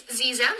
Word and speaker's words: Xexem: And then the other one is Zexem. It's spelled X-E-X-E-M. And it's spelled with an X, but it's pronounced with, like, a Z sound. Because, you know, the English language.